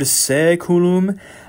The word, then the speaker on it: saeculum: Okay, and the word saeculum. There are several syllables in Latin that have an A-E. You might as well just cross out the A because the sound is E, like the English word let.